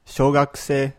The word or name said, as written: shougakusei